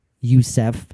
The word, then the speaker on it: Joseph: The family of the Yusufs, Y-U-S-U-F, went through a hell of a lot. After surviving the Titanic, Mary Joseph Youssef. It's spelled Joseph, but it's pronounced Youssef.